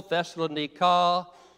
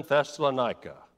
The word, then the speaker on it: Thessalonica: Modern pronunciation is Thessaloniki, biblical Thessalonica, American Thessalonica.